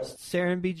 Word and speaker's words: Serenbe: So, we're going to talk to him about that based in Serenbe, Georgia. Did I say that right? – You got it, Joe. Serenbe, Georgia.